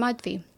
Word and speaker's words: Madhvi: Oh my God, like I usually introduce myself and I'll be like, hi, I'm Madhvi. And people hear it Marjorie.